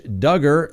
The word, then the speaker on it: Duggar: Second, Josh Duggar rhymes with hugger.